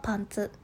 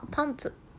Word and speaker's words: パンツ: ズボンはパンツ、下着はパンツと言って、あの、これからもね、ちょっとこのイントネーションで分けていこうかなと思っております。